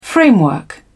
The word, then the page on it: framework: British English